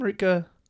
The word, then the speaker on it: Rutger: Is that how you say his name? Rutger. – I would say it like that.